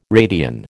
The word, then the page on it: radian: American English